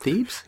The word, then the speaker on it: Thebes: And there's even one example of thieves, you know, thieves. How do you pronounce that? Thebes? – I read Thebes.